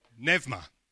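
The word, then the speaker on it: Nevma (πνεύμα): I'm looking at the Greek over there thinking I'm bound to get told off later for pronouncing it all wrong. A lot of help you are. Yeah. Nevma. Ah, okay, there you go.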